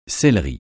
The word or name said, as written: céleri